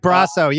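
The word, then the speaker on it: Brosseau: One of the frustrating things against Tampa is like last year, what was that guy, Brusso? Is that how you pronounce it? – Mike Brasso, yeah.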